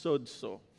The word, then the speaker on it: sozo: You see, the word healed in Greek is sozo, or it's pronounced sozo.